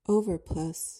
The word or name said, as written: overplus